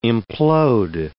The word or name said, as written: implode